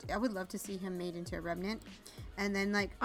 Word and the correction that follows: revenant: I would have loved to see him as a remnant, because I can't say that right. Remnant. – Revenant.